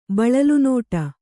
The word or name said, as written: ಬಳಲುನೋಟ